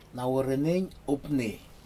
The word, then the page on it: nawuranig upni: Aneityum